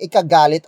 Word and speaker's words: ikagalit: Another example, huwag ikagalit ang opinyon ng iba.